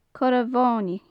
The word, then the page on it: corra mhonaidh: gaelic